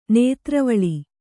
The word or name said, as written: ನೇತ್ರವಳಿ